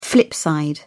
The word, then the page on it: flip side: British English